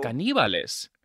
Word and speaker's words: Caníbales: The accent is on the I. – Oh. – Caníbales.